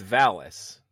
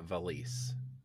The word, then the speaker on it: Valis: It's Valis, not Valise.